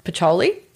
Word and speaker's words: Pacioli: So bookkeeping, just as an FYI, was a concept that was invented around 500 years ago, actually by a monk named Luca Pacioli. I think I'm saying that correctly. Mm. Yes.